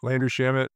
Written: landry SHAMET

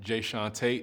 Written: Jaesean Tate

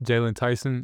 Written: Jaylon Tyson

